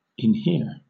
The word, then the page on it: inhere: British English